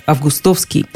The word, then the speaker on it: августовский: Но тут нам предлагается еще один допустимый вариант – августовский. Что означает «допустимый»? То, что он тоже в пределах литературной нормы, и ошибки особой, в общем, не будет. Можно предположить, как возникло это ударение «августовский». Произносить «августовский» не слишком удобно. Много слогов после ударного – Приходится напрягаться, как-то выговаривать. Намного легче, когда ударение в середине слова «августовский».